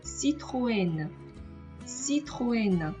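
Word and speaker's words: Citroën: 所以在这里，我们应该把它读成 Citroen，Citroen。这个就是雪铁龙的法语的发音了。那我们不能把它读成 Citroen 啊，因为我们知道 a n 在法语当中是一个字母组合。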